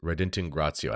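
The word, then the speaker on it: Redintegratio: So, for our purposes, I think we should look at Unitatis Retingratio, Article 6, and see if that's actually what happens. So here is the document Unitatis Redintingratio. I was saying it wrong. It's not Retingratio, Rediting, Reditingratio.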